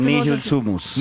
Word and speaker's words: Nihil sumus: ¿Cómo se pronuncia este Nihil sumus. – Nihil sumus. – ¿Cómo? – Nihil sumus.